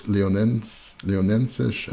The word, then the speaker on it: Leonenses: And the other possibility that you could win for a pledge of $20 or more is the Pedro Lima, Conjunto Os Leon, how did you pronounce that? Leonensis? – Oh, is that the name? I read something else. Okay. So the full title is Conjunto Os Leonenses and Pedro Lima.